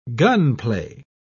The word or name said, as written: gunplay